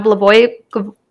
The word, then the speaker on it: Blagojevich: June 2007, Governor Rod Blagojevich.